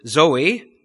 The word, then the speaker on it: zoe: Our English word life comes from a Greek word zoe, which is spelt Z-O-E.